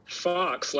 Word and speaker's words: Fox: Fox, like the animal.